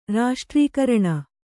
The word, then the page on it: ರಾಷ್ಟ್ರೀಕರಣ: raṣṭrīkaraṇa